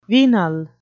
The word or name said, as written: venal